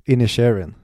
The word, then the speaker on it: Inisherin: jag har Colin Farrell i Banshees of Inisherin. In Hur uttalar man det? Inisherin. Inisherin, just det.